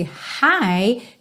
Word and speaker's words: high: Lembrando, esse high tem a mesma pronúncia do hi, hello, how are you doing? Ok? High. Então esse GH você não precisa se preocupar com ele.